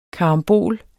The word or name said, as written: KARAMBOL